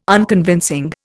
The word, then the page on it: Unconvincing: Indian